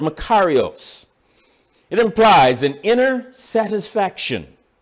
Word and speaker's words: makarios: The word blessed, or if you prefer the two-syllable pronunciation, blessed, in the Greek is makarios, M-A-K-A-R-I-O-S, makarios.